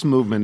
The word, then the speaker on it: Movement: Movement, it's pronounced Movement. It is spelled MVMT when you want to go to the .com stuff.